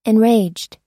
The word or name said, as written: enraged